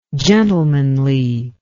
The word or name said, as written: gentlemanliness